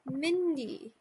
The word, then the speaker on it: Mindee: So without further ado, please, everybody, welcome Mindee.